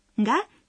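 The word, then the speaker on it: Га (が): «Га» – частица, оформляющая подлежащее. «Га» произносится в нос, но вас поймут, даже если вы произнесете просто «га».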